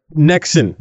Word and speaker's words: Nexen: Is it Nexian or Nexen? – Nexen tires. – Yes.